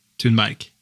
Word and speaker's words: Thunberg: You wouldn't say that in Scandinavian, but you can say it like that. – How would you say it? – Greta Thunberg.